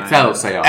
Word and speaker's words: Excelsior: Excelsior.